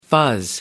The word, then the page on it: fuzz: American English